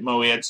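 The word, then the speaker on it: moed (מועד): Now that's M-O apostrophe E-D-S is the transliteration of the Hebrew word moed. Okay? Moed or mead is pronounced slightly differently. She pronounces it moed, so that's the way I'm going to pronounce it because she's taken Hebrew and I haven't.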